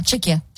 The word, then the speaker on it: Czechia: say it, you Googled it, Czechia? – Oh, yeah, Czechia. – Czechia, okay, I said it right.